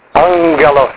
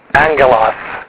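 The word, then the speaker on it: angelos (ἄγγελος): You've got two gammas together, right? You recognize that. So it's going to usually be pronounced with a nunga, angelos, or angelos, if you're Erasmian.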